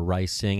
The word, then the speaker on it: Rysing: And I'm Rising on both, R-Y-S-I-N-G, Rising, or Rising, however you want to pronounce it.